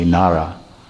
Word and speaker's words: Nada: So Nada is written Nada, but is actually pronounced in Bengali Nara. Nara means living entity, and Ayan means resting place. Ayan means A-Y-A-N-A, Ayan means resting place, or the abode of, the source of, or it means to sleep.